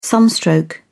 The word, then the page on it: sunstroke: British English